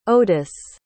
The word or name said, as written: odis